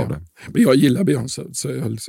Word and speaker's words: Beyoncé: Jag säger Beyoncé.